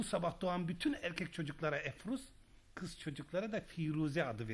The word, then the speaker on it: Efruz: İstanbul kadınlarının çoğu sizin adınızı telaffuz edemiyor. Sizden bahsederken Efruz Bey yerine Aforoz Bey diyorlar.